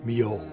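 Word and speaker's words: Milhaud: The focus of this edition of Singing and Other Sins is Darius Mio, who was born on September 4, 1892 in Aix-en-Provence, France, and died in 1974.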